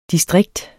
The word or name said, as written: distrikt